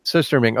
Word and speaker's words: surströmming: How do you say it? – Surströmming.